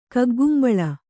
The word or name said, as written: ಕಗ್ಗಂಬಳಿ